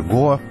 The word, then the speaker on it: Гоа: Рассказ моей коллеги Лили Пальвелевой о книге «Доктор Гоа». Кстати, именно так, вернее, произносить название этого места с ударением на «о» завершит этот итоговый выпуск программы «Время свободы».